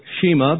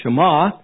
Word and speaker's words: Shema: In fact, for a few centuries, just before the arrival of Jesus on the scene, every pious Jew quoted the Shema. We might pronounce it Shema, but the Hebrew is Shema.